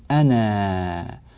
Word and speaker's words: أنا: نعم، كذلك مما ننبه إليه في الآية التالية لها 52 قوله تعالى أم أنا خير، كلمة أنا هذه كتبت بألف، أنا ألف بعد النون، لكننا لا ننطق هذه الألف في حال الوصل،